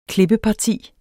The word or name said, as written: klippeparti